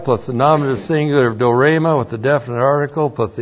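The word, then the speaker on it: dorema (δώρημα): D long O R long E M A. Dorema.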